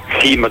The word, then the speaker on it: Sima: E quello che abbiamo appena ascoltato invece era una produzione di Alex Cima o Sima, come si pronuncia? – Sì. – Bene? – Sima, dovrebbe essere Sima in realtà.